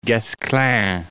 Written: Guesclin

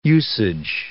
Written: usage

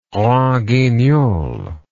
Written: grand guignol

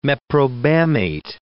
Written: meprobamate

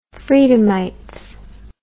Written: freedomites

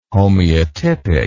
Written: homeotypic

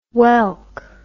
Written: welk

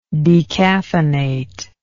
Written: decaffeinator

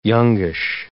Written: youngish

